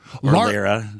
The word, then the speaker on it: Lara: Laura Trump. Lara. How do you say it? L-A-R-A.